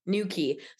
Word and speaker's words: Newquay: This is how you say it. Newquay.